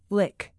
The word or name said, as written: lick